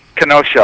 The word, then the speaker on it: Kenosha: Did I pronounce that right? Kenosha? – Kenosha. – I'm sorry. Okay. Kenosha.